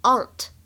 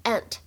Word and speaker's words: aunt: 在这段台词朗读过程当中呢，首先我们注意一下“ 姑姑”“ 姑妈” 这样一个单词，在英式英语当中呢，读作 aunt, aunt。而在美式英语当中呢，读作 ant ant。